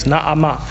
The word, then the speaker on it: Naamah: You pronounce both A's, Naamah.